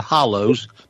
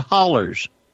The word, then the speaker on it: holler: They're not hollows. They're hollers. I congratulate you on that.